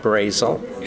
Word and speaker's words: Brasile: Yeah, Jim Brazel. – Jim Brazel. Could you spell that last name for us, please? B-R-A-S-I-L-E.